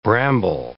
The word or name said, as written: bramble